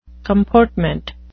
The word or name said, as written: comportment